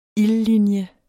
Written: ildlinje

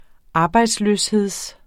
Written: arbejdsløshed

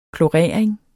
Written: klorering

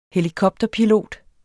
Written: helikopterpilot

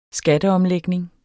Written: skatteomlægning

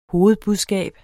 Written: hovedbudskab

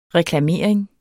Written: reklamering

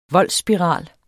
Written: voldsspiral

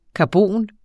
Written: karbon

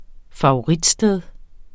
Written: favoritsted

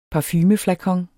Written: parfumeflakon

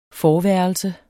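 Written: FORVÆRELSERNE

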